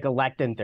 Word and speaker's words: Galectin: Talk to us about what you guys are working on over at Galactin Therapeutics. – Hi, Matt. Hi, Paul. Thank you for having me today. It's we pronounce it Galactin Therapeutics.